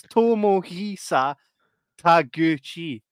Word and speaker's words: Tomohisa Taguchi: Right, so it's Tomohisa Taguchi.